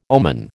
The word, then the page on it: omen: American English